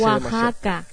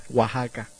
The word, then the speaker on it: Oaxaca: Se me hace Oaxaca. – Oaxaca.